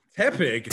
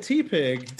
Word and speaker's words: Tepig: Tepig. – Tepig. – I always said T-Pig. I got T-pig.